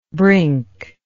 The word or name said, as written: brinkless